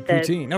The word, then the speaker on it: poutine: And the poutine, I think I'm saying that correctly, correct, right? – That's right, poutine.